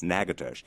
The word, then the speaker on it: Natchitoches: Now, we're talking about Louisiana country, not New Orleans, but sort of up north in Natchitoches, the funny pronounced name town that actually looks like it should be said Natchitoches, but it's Natchitoches.